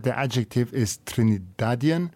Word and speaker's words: Trinidadian: But yes, The correct, you have said it correctly, it's Trinidadian.